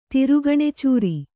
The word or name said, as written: ತಿರುಗಣೆಚೂರಿ